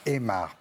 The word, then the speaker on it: Aymar: Ce qui m'amuse, c'est souvent première preuve, c'est Omar, oui. Émar, Omar. – non, c'est Émar, pas Omar. Ça ne me gêne pas, mais c'est pas Omar, c'est Émar. Voilà, ça s'écrit A-Y-M-A-R.